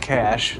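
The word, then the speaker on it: cache: In computer science, a cash, pronounced cash, this is what Wikipedia says, by the way.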